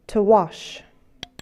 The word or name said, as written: wash